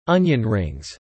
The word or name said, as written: onion rings